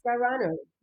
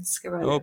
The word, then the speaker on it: Scarano: Scarano. Yeah, Dee Scarano. Yeah, my dad is Italian. This is an Italian Italian name, Scarano.